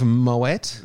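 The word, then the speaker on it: Moët: We have a bottle of Moet, which is the correct pronunciation.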